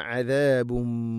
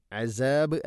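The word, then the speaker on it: عذاب: വലിൽ കാഫിരീന അദാബും മുഹീൻ, അസാബ് അല്ല അദാബ്, അദാബും മു തൻവീനു ശേഷം മീമു വന്നാൽ ഇത് ഗംബിഗുന്ന, അദാബും മുഹീൻ, അദാബും മു, ഇങ്ങനെ ഇത് ഗാം ചെയ്തുകൊണ്ട് ഉച്ചരിക്കണം. അദാബും മുഹീൻ, വലിൽ കാഫിരീന അദാബും മുഹീൻ.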